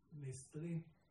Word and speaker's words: Nestlé: Nestlé, sí lo pronuncio bien, así se dice en Portugal.